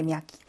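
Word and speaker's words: 미야키 (мягкий): 그다음 아까 연음보에서 미야키스나 그 부드러운이라는 의미를 가진 요 아이인데 미야키. 이것도 역시 여기에 등장하는 그는 흐 발음이 나서 미야키라고 읽으면 되겠습니다.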